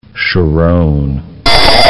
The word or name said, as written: Sharon